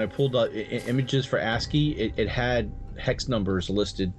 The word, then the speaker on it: ASCII: It was a tabletop tennis game, and, you know, all in ACSII. Am I saying that right? And, ASCII? – ASCII.